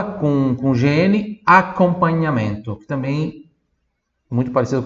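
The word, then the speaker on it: accompagnamento: Em português de fala acompanhamento, Italiano, acompanhamento. Esse A é um pouquinho mais aberto, tem dois C ali. Acompanhamento, e aí se escreve com GN.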